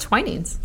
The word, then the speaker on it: Twinings: Twinings? – Does it have the IPA? – Yeah. – I can read it. Oh, it says Twinings. – Twinings.